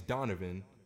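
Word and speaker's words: Donovohn: My name is Donovan, okay.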